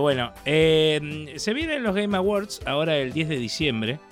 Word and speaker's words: Game Awards: Sí, los Game Awards. ¿Lo dije bien, Melina? – Aprobadísimo.